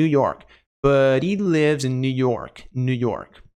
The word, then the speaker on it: New York: Pronúncia não é New York, é New York, New York, New York, New York, New.